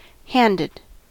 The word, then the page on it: handed: American English